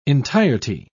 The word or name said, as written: entirety